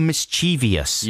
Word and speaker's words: mischievous: Well, I mean, that's not how you pronounce mischievous. – Mischievous. – Mischievous. Mischief. Mischief. I'd say it's flexible depending on where you come from. – No, no, no. – Mischievous. – No, not flexible.